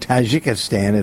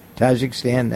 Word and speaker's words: Tajikistan: They came from Tajikistan, if that's how you pronounce it. Tajikistan, I think that's it.